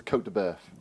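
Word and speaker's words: côte de bœuf: Now, this is a Cote de Boeuf. Have I said that right? – You have, yeah.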